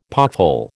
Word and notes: pothole: American English

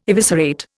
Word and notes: eviscerate: Indian

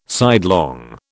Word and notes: sidelong: British English

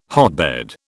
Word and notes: hotbed: British English

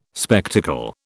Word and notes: spectacle: British English

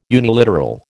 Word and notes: uniliteral: American English

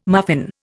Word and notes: muffin: Indian

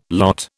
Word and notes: lot: British English

